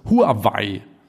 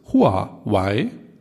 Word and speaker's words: Huawei: Genau, also was ich gesehen habe äh, oder was ich gehört habe, ist, dass sie beide äh, Aussprachen akzeptieren, aber die chinesisch richtige Aussprache ist Huawei.